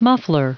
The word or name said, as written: muffler